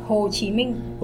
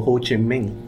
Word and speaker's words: Ho Chi Minh: Did I pronounce that correctly? Ho Chi Minh? – Ho Chi Minh. – Oh, Ho Chi Minh. – Yes.